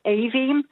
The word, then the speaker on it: Aveen: My daughter's friend lives in America, and her daughter's called Aveen, but they spell it A-V-E-E-N.